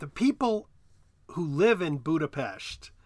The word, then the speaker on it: Budapest: Well, the way I've always pronounced it is Budapest, sort of like an SHT sound. But I will say that I'm not completely sure on that. – Well, you're correct. It's Budapest.